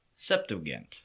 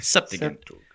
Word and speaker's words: Septuagint: Septuagint. – Okay. Septuagint.